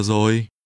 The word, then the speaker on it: rồi: Bây giờ mấy giờ rồi? Rồi. Rồi. – Already is pronounced with a falling tone.